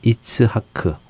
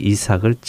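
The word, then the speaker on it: 이츠하크 (יצחק): 그들은 이렇게 웃었고 하나님께서는 그 아들의 이름을 웃음이라는 차하크에서 나온 이츠하크, 우리 발음으로는 이삭이라는 이름으로 지으라고 하셨지요. 그런데 지금 이스마엘이 이삭을 차하크 하는 것입니다.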